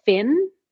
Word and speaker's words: Phyn: And it's a device called Fin. It's spelled PHYN.